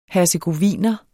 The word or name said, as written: hercegoviner